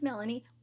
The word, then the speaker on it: Mellanie: So yeah, my name is Mellanie with two L's.